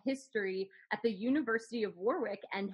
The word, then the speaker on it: Warwick: So I am, as you say, at the University of Warwick. We don't pronounce the second W in British English.